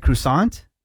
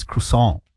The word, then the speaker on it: croissant: Is it croissant? – Croissant.